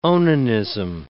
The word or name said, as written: onanism